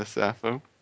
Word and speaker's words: Sappho: Laozi, Shakespeare, Austin, Dogen, Plato, Dante, Krishna, is it Sappho? – Yeah, Sappho.